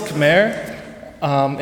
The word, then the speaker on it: Khmer: Yeah, so it's spelled Khmer in English, but it's pronounced Khmer, and it draws a lot of influences from the surrounding countries.